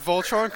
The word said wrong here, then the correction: Voltaren: Voltaren cream.